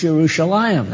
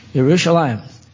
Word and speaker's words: Yerushalayim: You have come to the heavenly Jerusalem, which it properly is pronounced Yerushalayim. That's the way it's supposed to be pronounced. Yerushalayim.